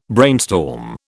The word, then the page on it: brainstorm: British English